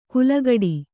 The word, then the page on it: ಕುಲಗಡಿ: kulagaḍi